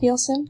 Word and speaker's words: Nielsen: And Nielsen is spelled N-I-E-L-S-E-N. Because people always get that wrong because it's the Danish spelling of Nielsen.